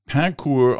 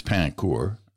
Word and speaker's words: Pain Court: Anyway, Chuck Belanger in Pancourt, Ontario. Now, I think it's Pancourt. It looks like Pain Court. Historically, it's a French-speaking village, so it's likely pronounced as Pancourt, which means shortbread.